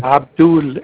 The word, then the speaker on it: Abdul: Abdul, A-B-D-U-L. – A-B-D-U-L, Abdul. – A-A-B-A as in Alpha, B as in Boy, D as in David, U as in Union, L as in Lima.